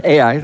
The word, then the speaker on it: Ai: I don't know how you say it in Spanish, Ai. But it's two letters, the letter A, the letter I. Ai, that's how we pronounce it, or I pronounce it.